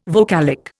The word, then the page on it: vocalic: Indian